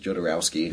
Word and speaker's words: Jodorowsky: and prepping for our talk, that how do you say his name? – I pronounce it Jodorowsky.